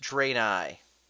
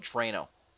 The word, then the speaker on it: Draenei: But it's pronounced Draenei. Thank you, Professor Mechahawk. I was going to lose so much sleep tonight not knowing how to pronounce it. I was going to go into work tomorrow and say, boss, for the life of me, I don't know how to spell this thing. How do you pronounce this? – Draenei? What the fuck? Get to work. – No. But I'm glad now that I'm going to write that down. Draenei. Great. I spelled Draeno.